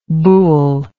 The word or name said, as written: Boole